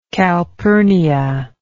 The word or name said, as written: calpurnia